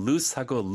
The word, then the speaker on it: loose: 가끔 loose하고 lose, 잃어버리다 헷갈릴 수 있거든요. – 네. – 그러니까 loose, 헐렁한 거는 O가 두개 있고 네. – S 발음이 S 발음이에요. Loose, loose, your shirt is loose, your pants are loose.